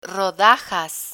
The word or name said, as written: rodajas